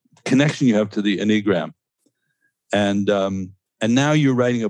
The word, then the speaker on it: Enneagram: Enneagram. – I think, I don't think it's Enneagram. It's Enneagram. Okay. – Enneagram. So accent on the first syllable.